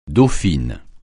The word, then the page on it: dauphine: francais